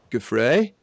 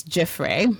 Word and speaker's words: Giuffre: and that's around Virginia Giuffre. I've said it wrong. How am I supposed to say that name? – Well, I'm humbly submitting it's Giuffre.